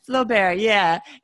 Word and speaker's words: Flaubert: I'd heard Flaubert. – Of it. – Flaubert.